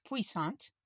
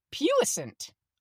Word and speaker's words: puissant: This word I have always pronounced puissant because that is the French way, but I looked it up and listened to the pronunciation and it's puissant, apparently.